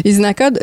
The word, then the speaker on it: Uzinakod: Usinacode, je tiens à le spécifier, en fait, ça s'écrit U-Z-I-N-A-K-O-D.